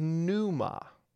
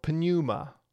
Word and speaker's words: pneuma: The Greek word is pneuma or pneuma, if you want to be strict in your pronunciation.